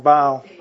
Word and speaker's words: Baal: Hey Baal? Close. Okay, Baal. Oh, I'm pronouncing it wrong. Baal. B-A-A-L. Yeah. Yeah, it's not B-A-Y-L. B-A-L-E.